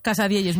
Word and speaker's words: casadielles: Y nos trajo una cosa que se llama Casadielles, ¿no? ¿Lo digo bien? – Casa Dieyes, muy bien dicho.